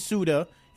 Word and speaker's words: Sude: If you just look at it, it looks like it's J U L I E and then S U D E, but it's Yuli Suda is how they pronounce it over in Germany.